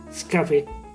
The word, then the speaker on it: Scapé: Questo è il gioco di parole che però si lega anche al vocabolo inglese escape, infatti in spagnolo scappé si pronuncia scapé.